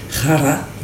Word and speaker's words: Rara: C'est-à-dire, je le dis pas très bien, normalement c'est Rara, mais c'est difficile à prononcer. – Ouais, je roule très mal les R, donc je vais éviter de m'humilier en disant juste Rara, voilà.